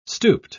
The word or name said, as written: stooped